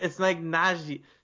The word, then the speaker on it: Nagy: Nagy? – It's like Najee. She said it in the intro, and I was like, oh wow, that wasn't what I was expecting.